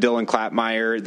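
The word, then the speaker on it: Dylan Klapmeier: Again, for the record, I'm Dylan Klapmeyer, the governor's education policy advisor. Last name is spelled K-L-A-P-M-E-I-E-R.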